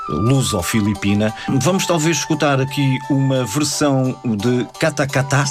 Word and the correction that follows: Katakataka: Sim, Katakataka. – Katakataka. – Isso. – Falta uma sílaba.